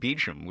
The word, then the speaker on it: Beauchamp: Oh, well, actually, it's pronounced Beecham, which is weird. – Beecham. Okay. – Yeah, because it's spelled like the French Beauchamp, so you think.